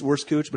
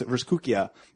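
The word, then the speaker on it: Wurstküche: If you go to a place like a Vrskukia, which we've had on the podcast on Top Shelf, it sounds like it's Vrskuch, but it's actually pronounced Vrskukia.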